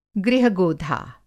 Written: गृह-गोधा

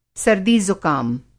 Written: सर्दी ज़ुकाम

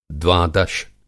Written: द्वादश

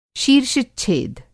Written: शीर्षच्छेद

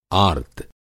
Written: आर्त्त

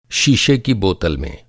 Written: शीशा

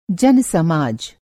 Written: जन-समाज

